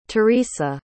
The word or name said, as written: teresa